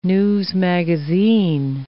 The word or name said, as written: newsmagazine